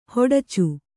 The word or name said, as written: ಹೊಡಚು